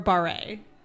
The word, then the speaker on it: barre: Okay, now that we got that out of the way, is it pronounced bar or barre? – I think bar. – Okay, bar. Cool.